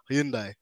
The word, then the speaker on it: Hyundai: And also, you mentioned there as well, like working with Dallara, which is interesting because now I know, well, you guys work with Hyundai, or Hyundai as the pronunciation goes.